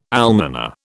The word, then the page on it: almoner: British English